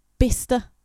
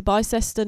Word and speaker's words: Bicester: But I like for years I thought I was going to Bicester, not Bister.